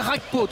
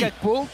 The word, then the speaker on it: Gakpo: Par exemple, non, je vais pas la jouer Rakpo, tu vois. Je vais dire Gakpo. Non, parce que après, sinon, ça veut dire qu'on va prononcer vraiment la néerlandaise et là, on n'y arrivera pas.